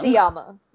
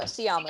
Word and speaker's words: Sciamma: Sciamma. No, Sciamma. That's correct.